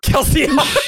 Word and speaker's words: Kelsier: Kelsier. – The so, best pronunciation.